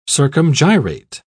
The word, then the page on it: circumgyrate: British English